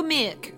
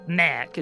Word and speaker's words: Meerk: Is it Merc or Mirk? I thought Bryn keeps saying Mirk. – I'm pronouncing it incorrectly. – I mean, it's Indraconic and it's a transliteration. – Okay. – So we're doing our best. – I get the impression it's more Merc, isn't it? – Yeah, it's like Merc. A cobbledish, draconic-ish sound. I've spelt it M-E-E-R-K.